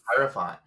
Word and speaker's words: Hierophant: Hierophant.